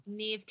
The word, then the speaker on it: Niamh: It starts with an N, Nime? – Niamh Kavanagh.